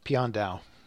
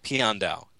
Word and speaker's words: Piandao: Pian Dao. – Pian Dao, just like it's spelled, duh.